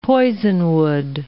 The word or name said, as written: poisonwood